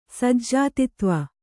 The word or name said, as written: ಸಜ್ಜಾತಿತ್ವ